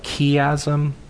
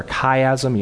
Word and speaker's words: chiasm: And in fact, we've talked about this before, that there's a literary device called a chiasm or a chiasm. You'll hear it pronounced either way.